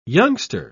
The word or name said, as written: youngster